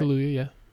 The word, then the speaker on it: Kaluuya: Am I pronouncing his name right? – Kaluuya, yeah.